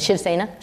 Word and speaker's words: Shiv Sena: Shiv Sena.